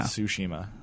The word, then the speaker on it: Tsushima: Ghost of Tsushima. – Tsushima. – All right, It's spelled with a T.